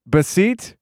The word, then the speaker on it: Bassitt: Julio Teheran's got to pronounce Bassitt. – Bassitt? – Yeah.